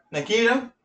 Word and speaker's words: Nikita: Nitka. I can't say that name. Go ahead. – Nikita? – Wow, I would have fucking butchered it. – How, no, dude? – La Femme Nikita. Hello? – I would have said, Nitka.